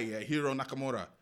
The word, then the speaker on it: Hiro Nakamura: Because there's one guy, Hiro Nakamura, he can teleport.